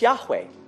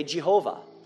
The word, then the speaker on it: Yahweh: Okay, and you will notice that whenever something is in all caps, L-O-R-D, that's God's covenant name. Anyone want to try to pronounce it? It's Yahweh. Or the old Puritans would say Jehovah.